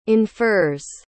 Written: infers

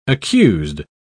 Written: accused

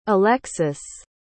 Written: alexus

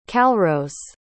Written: calrose

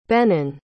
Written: Benon

